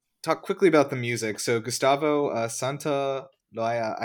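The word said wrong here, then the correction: Santaolalla: I don't know if I said that right. Santa Loaya? – Santa Olaya. – Santa Olaya, okay.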